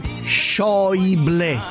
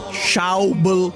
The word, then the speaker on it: Schäuble: Si dice Schäuble. Non è difficile. Schäuble, Schäuble, Schäuble, Schäuble. Noi inglesizziamo tutti. Non è difficile. Schäuble. Tutte le lettere vanno le. A U si legge OI.